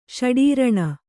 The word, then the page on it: ಷಡೀರಣ: ṣaḍīraṇa